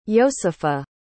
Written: yosepha

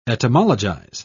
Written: etymologize

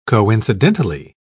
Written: coincidentally

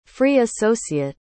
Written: free-associate

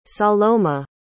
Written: saloma

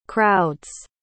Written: crowds

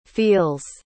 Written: feels

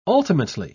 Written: ultimately

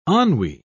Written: ennui

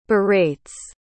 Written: berates

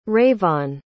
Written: Rayvon

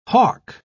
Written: hawk

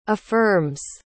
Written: affirms

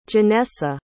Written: Janessa

